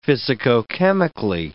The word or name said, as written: physicochemically